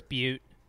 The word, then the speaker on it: Butte: Yeah, I mean, from a Pitchfork quote, there are Oregon-specific references to Eugene, the Tillamook Burn forest fires, Spencer, B-U-T-T-E, I don't know how to say it. There, I think it's Butte.